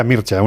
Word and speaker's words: Mircea: Sí, se pronuncia Mircea, uno que es muy friki de todo lo rumano, ya lo sabéis.